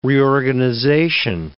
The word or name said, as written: reorganization